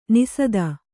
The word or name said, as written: ನಿಸದ